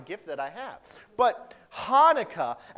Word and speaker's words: Hanukkah: We don't spell it with the C. You know why? Because we have no sound in the English language. It requires a lot of phlegm, all right? So we drop the C. So it's actually said Hanukkah is how you say it.